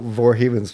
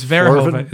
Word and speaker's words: Verhoeven: Well, the one thing I like about Verhoeven's films is he always has something in them. – Do we have to? Shouldn't we agree on how to pronounce his name? – It's Verhoeven. It's Verhoeven.